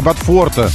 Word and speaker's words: Бофорта: Правильно говорить Бофорта, потому что Бофорты же или Бофорты.